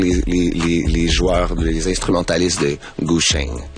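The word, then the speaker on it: Gu >> Zheng (古筝): Et il y a énormément de Gu Zheng. Ça se prononce Gu Zheng. – Ouais, Gu Zheng. C'est plus un CH, un TCH, mettons.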